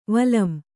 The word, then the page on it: ವಲಂ: Kannada